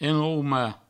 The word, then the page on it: enraumar: enrauma; provençal